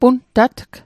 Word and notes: puntatg: Mi'gmaq

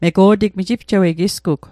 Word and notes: mego'tig: Mi'gmaq

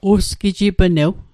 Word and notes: usgijipnew: Mi'gmaq

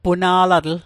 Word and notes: puna'latl: Mi'gmaq

